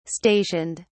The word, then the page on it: stationed: British English